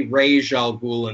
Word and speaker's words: Ra's al Ghul: And I say Ra's al Ghul in this case because that's how the series that's how the series pronounces it.